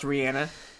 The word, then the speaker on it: Rihanna: And it's announced Rihanna. That's how you say it.